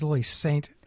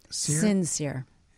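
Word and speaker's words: Lily St. Cyr: And by the way, her name is Lily Sincere. – Sincere. – It's pronounced that way, but it was.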